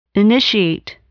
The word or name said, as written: initiate